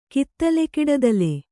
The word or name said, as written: ಕಿತ್ತಲೆಕಿಡದಲೆ